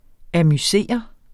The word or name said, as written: amusere